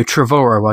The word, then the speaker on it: Trevorrow: I read somewhere that he said it rhymes with tomorrow. So Trevorrow, I guess it is.